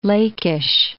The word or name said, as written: Lachish